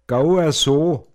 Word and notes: gu na scheikeg: Pinzgauer Mundart

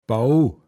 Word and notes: vase-ichn: Pinzgauer Mundart

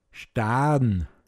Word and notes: Staa(r)n: Pinzgauer Mundart

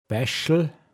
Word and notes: Bache: Pinzgauer Mundart

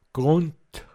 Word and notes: oaschichteg: Pinzgauer Mundart

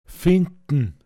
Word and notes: foama: Pinzgauer Mundart

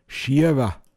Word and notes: schäich: Pinzgauer Mundart

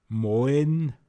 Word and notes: Müller: Pinzgauer Mundart

